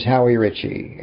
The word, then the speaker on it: Howie Richey: My name is Howie Richey.